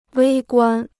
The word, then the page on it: 微观: wēi guān